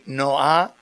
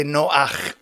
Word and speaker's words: Noach: By the way, you don't say Noah, we say Noach with a ch in the end.